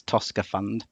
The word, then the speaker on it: Tosca Fund: Well, my finance partners would be a little bit upset if I didn't just quietly correct the pronunciation. It's Tosca Fund.